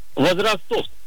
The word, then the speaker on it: возрастов: Возрастов. – Дорогой мой человек, ударение в данном слове всегда падает только на первый слог. Поэтому правильно говорите возрастов, возрастов.